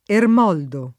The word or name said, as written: Ermoldo